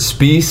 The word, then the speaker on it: Spiess: You can call me Kurt Spies. – Spies. – S-P-I-E-S-S.